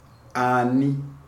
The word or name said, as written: Ani